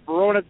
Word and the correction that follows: Venona: files, the Venona files.